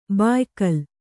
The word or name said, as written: ಭಯಾಕುಲೆ